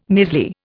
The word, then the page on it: measly: Indian